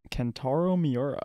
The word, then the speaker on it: Kentaro Miura: Kentaro Miura. – Kentaro Miura, the creator, author of the famous manga Berserk, died a few weeks ago, but we only found out.